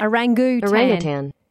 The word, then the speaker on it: orangutan: Orangutan. – Orangutan. – Orangutan. – Orangutan. – But they don't say tang, is my point. And I think we're incorrect in saying orangutan.